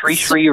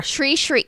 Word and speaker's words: Sri: Sri Ravi Shankar. – Yep, Sri right? Sri yeah. Ravi Shankar. – Sri Sri. So yeah, it rhymes with tree yeah. tree.